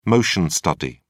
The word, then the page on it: motion study: British English